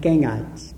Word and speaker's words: Gangites: This river is called, I pronounce it Gangites, G-A-N-G-I-T-E-S.